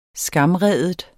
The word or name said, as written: SKAMRIDE